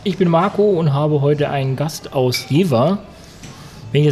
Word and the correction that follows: Jever: Also man spricht Jever eigentlich als Jefer aus. Jefer. Hm, Jefer, genau. Jefer.